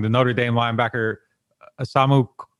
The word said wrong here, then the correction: Jeremiah Owusu-Koramoah: Jeremiah Owusu-Koramoah.